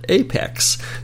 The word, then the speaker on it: APX: I've always pronounced it APX. That's what it says right on the cover. APX, but I have learned that the folks at Atari call it Apex.